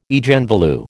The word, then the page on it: Eigenvalue: American English